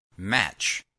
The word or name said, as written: match